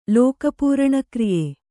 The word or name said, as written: ಲೋಕಪೂರಣಕ್ರಿಯೆ